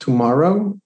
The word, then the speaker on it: TMRW: But there's one big company out there that I've been following over the past 3 years called Tomorrow, and it's spelled TMRW. And